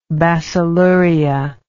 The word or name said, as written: bacilluria